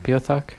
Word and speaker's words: Beothuk: Did I pronounce it right, Beothuk? – I think that's how you pronounce it if you don't want people to giggle.